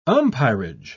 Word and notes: umpirage: British English

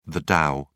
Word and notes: dow: British English

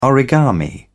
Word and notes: origami: British English

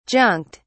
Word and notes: junked: British English

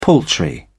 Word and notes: paltry: British English

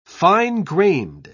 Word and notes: fine-grained: British English

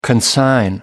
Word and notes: consign: American English

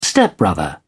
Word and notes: stepbrother: British English

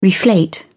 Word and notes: reflate: British English

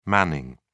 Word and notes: manning: British English

